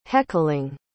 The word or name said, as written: heckling